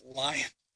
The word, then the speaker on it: Lion: Yeah, Ryan. Yes, Ryan. And Ryan or Lion? – Oh, Lion. No, it's Lion. Excuse me.